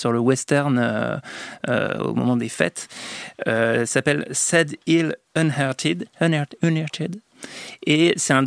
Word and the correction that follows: Unearthed: Unearthed, non je suis putain.